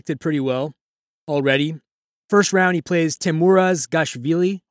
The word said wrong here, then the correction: Gabashvili: Hope I pronounced that correctly. – Gabashvili, thank you.